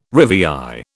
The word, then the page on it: rivière: British English